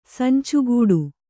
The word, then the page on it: ಸಂಚಗೆಡು: Kannada